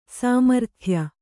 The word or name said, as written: ಸಾಮರ್ಥ್ಯ